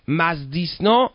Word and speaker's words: مزدیسنا: مزدیسنا تلفظ درستش میگن مزد یسنا.